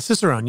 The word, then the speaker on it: Cicerone: Oh, and more importantly, certified Cicerone. Did I say that right? – Cicerone, yeah. – Fuck. – Everyone, no one says it right.